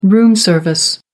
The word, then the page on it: room service: American English